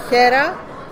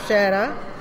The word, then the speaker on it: Χέρα: στο δίγημα μου που λέγεται Χέρα. Όποιοι ξέρουν ποντιακά ξέρουν ότι το χ προφέρεται λίγο βαριά, οπότε μπορείτε να το πείτε και Σέρα.